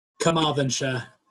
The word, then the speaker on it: Carmarthenshire: Now you're going to have to pronounce this for me, on, Carmarthenshire.